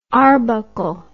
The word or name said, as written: arbuckle